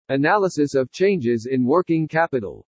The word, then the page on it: ANALYSIS OF CHANGES IN WORKING CAPITAL: english